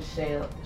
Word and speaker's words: Shia: He pronounces his name Shia.